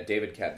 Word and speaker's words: David Koepp: Is that how you say his name? David Koepp? – Yeah, David Koepp.